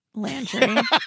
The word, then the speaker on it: Landry: How do you say his last name? – Landry.